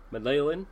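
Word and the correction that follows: Mäenalanen: Menelainen. There we go. I'm looking at it. Menelainen, I believe is how you say it.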